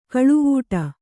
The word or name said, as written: ಕಾಳವಟ್ಟ